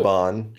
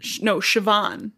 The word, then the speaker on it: Siobhan: But there's an Irish clan with Siobhan in the lead. It's B-H. – So, Siobhan. – Siobhan. – Yeah. – With a B. – But it's spelled Siobhan.